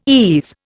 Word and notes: ease: Indian

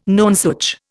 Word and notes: nonsuch: Indian

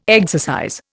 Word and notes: exercise: Indian

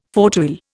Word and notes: fauteuil: Indian

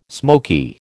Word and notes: smoky: American English

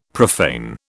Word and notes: profane: British English

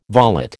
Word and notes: volet: American English

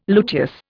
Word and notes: luteous: Indian

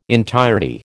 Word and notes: entirety: American English

